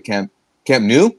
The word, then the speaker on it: Camp Nou: 90 Minute can also confirm that veteran Uruguay star Suarez, who was very close to Messi at Camp Nou, is that how you pronounce it? – Yeah.